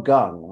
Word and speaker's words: Kang: His name was Jung Ho Gung, was how it was pronounced. They spelled his last name K-A-N-G.